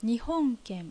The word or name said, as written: Nihonken